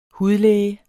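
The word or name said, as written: HUDLÆGEN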